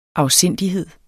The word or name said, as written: afsindighed